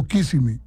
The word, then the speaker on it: Kissimmee: Así se pronuncia, ¿no? ¿Kissimmee o Kissimmee? – Kissimmee. – Kissimmee. Oh, bueno. Quiere conocer, sí, Kissimmee, que se escribe Kissimmee. – Correcto.